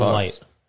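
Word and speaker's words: Leitch: Jason Light. It's just pronounced like light. – Oh. – Like light bulb. I don't know. – It's spelled differently, but yes.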